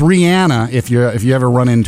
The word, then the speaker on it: Rihanna: I'm glad to hear this because there are artists whose names I pronounce wrong, and Rihanna is one of them. Everybody says Rihanna, but she's like, no, it's Rihanna.